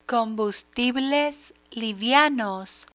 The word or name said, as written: combustibles livianos